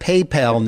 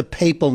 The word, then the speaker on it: Papal: You need to tell those guys who are the two anchors, the one guy whose name I can't pronounce, that it's not PayPal Ninja, it's the Papal Ninja, because he keeps getting it wrong.